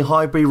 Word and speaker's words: Highbury: and obviously Highbury rhymes with library.